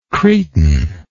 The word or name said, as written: cretinoid